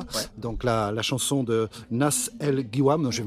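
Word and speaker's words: Nas El Gwiam (ناس الغيوان): Nas El Gwiam. – Nas El Gwiam, voilà. – Et quand il y a GH, tu dis re. Tu fais comme si c'était un R.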